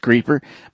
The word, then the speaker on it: Creepor: Okay, band number four is a band called Creeper, which is pronounced Creeper, but spelled C-R-E-E-P-O-R.